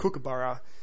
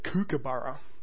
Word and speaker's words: kookaburra: The main problem Yanks and Canucks have with kookaburra is that they tend to pronounce it kookaburra, when the first syllable is actually cook, as in, my mother was a lousy cook.